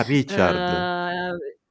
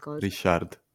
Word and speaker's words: Ryszard: Ah, Richard. Con la SZ, Richard.